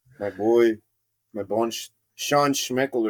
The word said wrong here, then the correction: Sean Schemmel: Sean Schemmel.